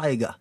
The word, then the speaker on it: Tyga: ja, for, for det første problemet her, tror du han heter Tyga eller tror han heter Tiger? – Tiger.